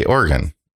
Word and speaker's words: Oregon: I never say, I don't know why I just said Oregon. – I know. That's why I looked at you. – I don't say like, Oregon. What? I say Oregon.